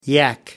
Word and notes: yak: American English

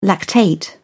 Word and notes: lactate: British English

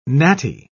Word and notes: gnatty: British English

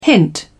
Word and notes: hint: British English

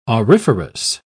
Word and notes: auriferous: British English

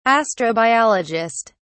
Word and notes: astrobiologist: British English

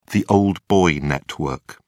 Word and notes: old boy network: British English

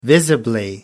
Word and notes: visibly: American English

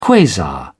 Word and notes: quasar: British English

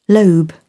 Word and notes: lobe: British English

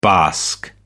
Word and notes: basque: American English

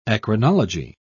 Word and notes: eccrinology: British English

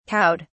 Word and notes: cowed: British English

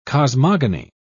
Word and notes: cosmogony: British English

